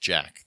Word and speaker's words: Sjak: And they comment all the time, but it's spelled S-J-A-K. It's pronounced Jack. The S is silent.